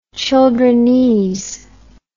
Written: childrenese

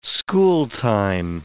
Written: schooltime

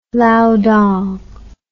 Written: lao dong

